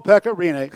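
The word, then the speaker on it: Pekka Rinne: And then I will never forget, it was probably 2005, some guy named, nobody could say his name right, Pecorino. – Yep. Well, we always used to tell the other broadcasters, think of that song, the Macarena. – Yeah. – Oh, Pecorino.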